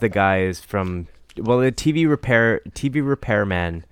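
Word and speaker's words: TeeVee Repairman: Yo, well, TV Repairman is maybe the best band name that I've seen in quite some time.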